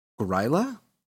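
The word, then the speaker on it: Grýla: But specifically like Krampus or, I think I'm pronouncing it this right. Gryla? G-R-Y-L-A?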